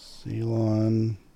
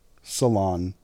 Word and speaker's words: Ceylon: Ceylon. It is. – Ceylon. – Okay. – Ceylon is.